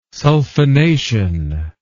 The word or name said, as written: sulfonation